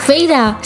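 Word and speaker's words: Feyre: Al final, cuando ya me hice la idea, sí, sí viene, ajá, porque a ah, Feire, ya ves que lo escribe Feire, pero se pronuncia. – Feira, sí, lo sé.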